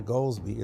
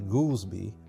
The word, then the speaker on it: Goldsby: And his name is not Goldsby, it's actually Goolsby, but people call it Goldsby because it's spelled G-O-L-D-S-B-Y.